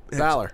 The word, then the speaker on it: Balor: It's Balor.